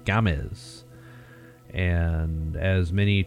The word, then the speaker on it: Games: So the big, giant news for us is that Alex Gomez, it's not games, even though it's spelled, you know, for you gringos, it's spelled G A M E S. That's like games, you know.